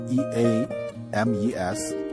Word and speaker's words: Eames: 啊，下一个朋友，这个叫 E A M E S，这个应该怎么念呢？Eames 啊。